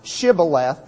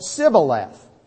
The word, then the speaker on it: Shibboleth: And so they said to them, "Okay, say the word Shibboleth." Well, they couldn't pronounce Shibboleth that way. They said Sibboleth.